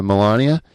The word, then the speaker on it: Melania: So it's like Melania Trump, or however you say her name. – Yeah, yeah, Melania.